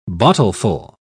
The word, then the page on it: bottleful: British English